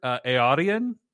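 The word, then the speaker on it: Eärien: So anyway, so we also meet Isildur's sister, Eärion. – I think that's how you pronounce it. Yeah.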